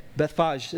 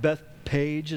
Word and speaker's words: Bethphage: I looked up how to say it. Bethphage is one of the ways to say it. Really weird. Not like Bethpage is probably what some American like me would say.